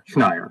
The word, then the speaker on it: Schneier: Schneier. It's rhymes with liar, flyer. – With an N. Well, it's Schneider without the D.